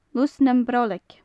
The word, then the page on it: lus nam braoileag: gaelic